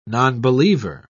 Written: nonbeliever

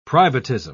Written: privatism

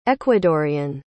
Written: ecuadorean

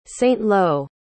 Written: saint-lô

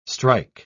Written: strike

